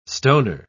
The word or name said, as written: stoner